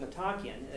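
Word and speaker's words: Metochion: And you have pilgrims, right, who come uh, to this Metakion, right, uh, or Metakion, that's how you pronounce it, Metakion.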